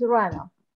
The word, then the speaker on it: Rana: Yeah, my first name is Rana.